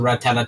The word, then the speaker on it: Rattata: I'm going to go C tier for Rattata. – No, it's pronounced ratata tata tata tata tata tata. Ratata.